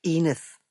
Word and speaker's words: Enith: Han var den eneste, der udtalte hendes navn på spansk, Enith, og han havde fortalt hende, at spanierne i Spanien, men ikke dem i Sydamerika, udtalte det sådan, fordi en af deres konger lispede, og de abede efter at bar hensynsfuldhed.